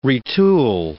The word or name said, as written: retool